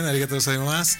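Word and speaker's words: arigato gozaimasu (ありがとうございます): Pues sí lo dijiste bien, arigato gozaimasu.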